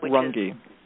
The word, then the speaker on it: Runge: Just Karen Runge. – Mm-hmm. And that's spelled R-U-N-G-E, right? – R-U-N-G-E, right.